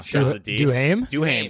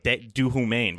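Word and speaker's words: Duhaime: Duhame. – Thank you. I want to say like Duhumain, but yeah, Duhumain. – That's kind of how it's spelled, right? It's spelled pretty weird.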